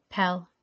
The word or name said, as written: Pel